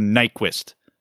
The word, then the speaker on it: Nyquist: Apparently, his last name is not pronounced particularly close to Nyquist. Apparently, the Y is a U sound in Swedish.